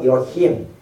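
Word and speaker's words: Elohim (אֱלֹהִים): 其中第一种的形式我们叫做我们读作 Elohim，这是占最多数，总共有 2570次。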